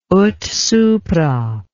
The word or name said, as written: ut supra